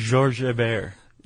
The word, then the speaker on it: Georges Hébert: A French naval officer named Lieutenant George Herbert. – I don't think that's how you say his name. – What would you say in French? – Georges Hébert. – Georges Hébert.